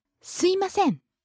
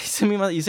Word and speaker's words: すいません: すいません. – Right. You don't say すみません. You say like すいません.